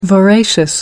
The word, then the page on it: voracious: American English